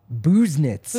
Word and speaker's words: Busenitz: Busenitz. – Busenitz. – Busenitz. Like, you know how you're like you pick nits, like you're being very particular and you're picking out something small, little nits.